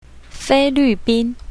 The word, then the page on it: fei1luu4bin1: Mandarin